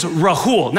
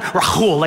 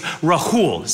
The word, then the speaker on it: Rahul: See, my name is Rahul, not Rahul like Habakkuk. Okay, it's Rahul, simple H.